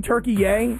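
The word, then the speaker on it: Türkiye: Listen, I liked saying Turkey Yay because I found this interesting that there was this new pronunciation of the country Turkey.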